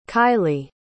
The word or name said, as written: kiley